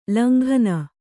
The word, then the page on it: ಲಂಘನೆ: langhane